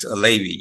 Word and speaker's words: Aleibi: Am I saying your name correctly? – That's correct, Aleibi, yes.